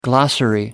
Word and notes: glossary: American English

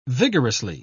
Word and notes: vigorously: British English

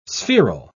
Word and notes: spheral: British English

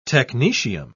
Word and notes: technetium: British English

